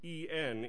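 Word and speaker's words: EN (ἐν): In the original language, that word by, God has spoken by his Son, it has two letters, the Greek E and the Greek N. N. It sounds like the word in, doesn't it?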